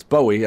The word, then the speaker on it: Bowie: Caitlin Moran can't decide what way to pronounce Bowie. She says Bowie or Bowie, but she says it either way. Of course, it's Bowie, as she should know.